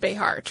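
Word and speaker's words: Béxar: The original word, Béjar, being Spanish, was most likely pronounced Béjar, treating the X like a J to sound like ye, ye, or ha.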